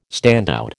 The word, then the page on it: standout: American English